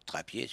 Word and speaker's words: Trappier: Johnson, et un batteur qui poussait bien le taux, et il s'agissait d'Arthur Trappier, ou d'Arthur Trappier, si on le prononce, à la créole.